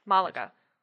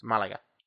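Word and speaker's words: Málaga: For those that are wondering, yes, it is Malaga, but that is not how it's pronounced. – No, there's an accent on the first A. Malaga. – It's Malaga.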